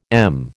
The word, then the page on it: em: American English